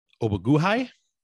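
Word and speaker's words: Ogbuehi: They brought over Al Woods from the Jags, Cedric Obaguhai. I don't know that I butchered that one. – That was a pretty good pronunciation, I would say.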